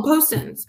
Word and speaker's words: Postens: Well, a pullover is not going to be, yes, Postens. Am I saying it correctly? I feel like, yeah, you said Post Malone, Postens. Yeah, I'm pretty sure.